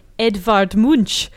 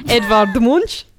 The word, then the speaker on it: Edvard Munch: On this day in 1994, the screen painting by Edvard Munch was stolen from the National Gallery in Oslo. – Wow, that's great pronunciation. – Thank you. Good, I don't know. – Top job. – Edvard Munch.